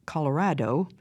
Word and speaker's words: Colorado: Colorado, pronounced Colorado in Spanish, simply means red, and these Indians were named because they painted themselves red from head to toe.